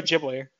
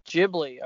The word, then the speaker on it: Ghibli: No, it's Studio Ghibli. – Ghibli.